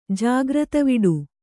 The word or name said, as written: ಜಾಗ್ರತವಿಡು